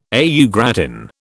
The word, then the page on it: au gratin: British English